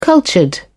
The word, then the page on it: cultured: British English